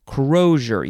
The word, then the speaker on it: crosier: Crozier. Yeah, that's how you pronounce it.